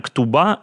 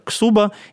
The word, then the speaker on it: ктуба: А, а, э, и это можно произносить так же, как «ксуба» или как «ксиба».